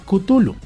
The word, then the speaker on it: Cthulhu: La aceptación más grande que tenemos es gracias a Metallica, pues las que las personas que son aficionados al metal, por el álbum que precisamente se llama Cthulhu, y pues se quedó como Cthulhu. entonces la idea es que ustedes pueden pronunciar Cthulhu como quieran en ese sentido.